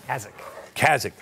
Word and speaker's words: Kazakh: Also in attendance were the Burisma executives, as well as Russian and Kazaki. How do you say it? Kazakh oligarchs. – Kazakh. – That's right. Kazakhstan.